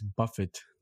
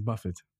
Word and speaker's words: Buffett: We have Elon Musk overtakes Buffett. Not Buffett. Wait, is it Buffett? Yes, it's Buffett. I was about to mispronounce it and say buffet.